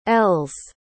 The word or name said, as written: l's